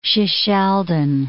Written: Shishaldin

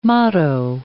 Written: motto

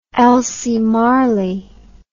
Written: Elsie Marley

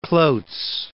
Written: Cloots

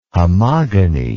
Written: homogony

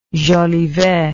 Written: Jolivet

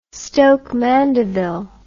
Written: stoke mandeville

